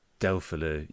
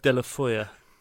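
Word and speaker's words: Deulofeu: How do you pronounce it, mate? – I'll consult Mark Masters for the correct... – De La Foya. – De La Foya. – It sounds like a cake. – It's certainly not Delphaloo.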